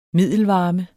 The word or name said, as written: middelvarme